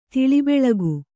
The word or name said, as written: ತಿಳಿಬೆಳಗು